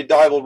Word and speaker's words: Dival: We were introduced from our friend Tanya Dival rhymes with Bible, right? – Yes.